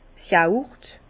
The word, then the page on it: d'r Yaurt: Alsatian; Bas-Rhin; Herrlisheim